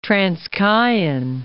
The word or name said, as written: Transkeian